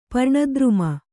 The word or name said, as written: ಪರ್ಣದ್ರುಮ